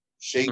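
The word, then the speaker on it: Schaech: So it stars John Cusack along with Cage, and then Adrian Grenier and Jonathan Sheck, I guess is how you say that. Shake. Mm-hmm. Looks like it should be Shake or whatever, but it's Shake or something.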